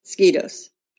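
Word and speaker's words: moscheutos: Well, I did a pronunciation thing, and it's pronounced like moss. Mosquitos, which is like a mosquito.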